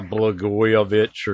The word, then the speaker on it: Blagojevich: Blagovich, Blagovich, Blagovich, what's his name? Yeah, Blagojevich or something.